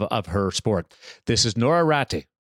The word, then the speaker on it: Noora Räty: So you really need to roll, roll your R. So it's Noora Räty. So two R's there that kind of get the North American people.